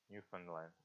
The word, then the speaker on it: Newfoundland: What's the right way to pronounce it? Newfoundland? Newfoundland, okay.